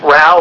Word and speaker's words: Row: This is Seth Rao. Last name is spelled R-O-W.